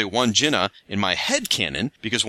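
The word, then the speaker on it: Wandjina: Says, just to chime in on the Aussie pronunciation of Wandjena, I've mostly heard it said Wandjena.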